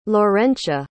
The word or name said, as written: lawrencia